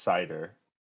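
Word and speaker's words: Seider: I think it's pronounced Cider. Maurice Cider? – What was I saying? Yeah. Cedar? – Yeah. – Yeah, I think you're right.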